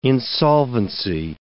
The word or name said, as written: insolvency